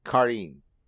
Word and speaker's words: Karin: Is it Karin or Karin in the Naruto games? – Karin. It's Karin.